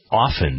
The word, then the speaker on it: often: When I hear people say often, it isn't often, it's often. The T is silent.